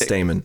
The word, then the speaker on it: stamen: No, stamen, it's pronounced stamen.